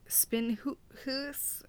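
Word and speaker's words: Spinhuis: So this one is at the old spinning house, which is all I'm going to call it because honestly, the name is Spin Hoos. – I can't see the word you're trying to pronounce. – S-P-I-N, so spin. H-U-I-S.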